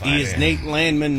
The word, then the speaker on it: Landman: It is Nate Landman, not Landman. He is not Billy Bob Thornton.